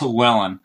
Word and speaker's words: Llewellyn: but it's actually like Llewellyn. You know, it's like the double L is like a Q almost when you pronounce it in Welsh.